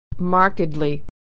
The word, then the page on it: markedly: ed